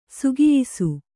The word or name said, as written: ಸೊಗಯಿಸು